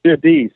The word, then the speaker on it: Jurdi: Yes, it's so funny that you say that, because Jeudi means Thursday in French. – Okay. – So it is a very common, common occurrence. And many people do pronounce the name Jurdi, so it's perfectly fine with me.